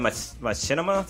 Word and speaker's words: Machinima: So, the director from Fast and the Furious is going to be making a return of Knight Rider to Machinima.